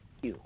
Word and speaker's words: Pugh: Pugh, P-U-G-H. – Pugh, okay.